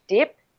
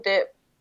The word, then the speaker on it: Dieppe: Um, this was um, I had taken one rest uh, day in a Dip Dip I think it's called um, in France, it's like it's where you sail, it's where the ferry goes to. So it's it's spelled uh, D I E P P E.